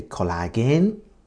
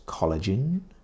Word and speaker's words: collagen: Pronunciation, Japanese say collagen, but the correct pronunciation is collagen.